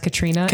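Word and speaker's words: Caitriona: I've had several people contact me on the internet to tell me that Balfe's name is pronounced Katrina.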